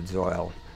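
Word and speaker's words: Zeuhl: And I don't know if everyone pronounces Zoil like that. It's Z-E-U-H-L. I pronounce it Zoil.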